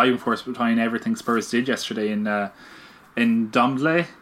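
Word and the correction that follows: Ndombele: Can I say that right? In Dom- in Dombele, yeah. – In Dombele. It was more what we said last week.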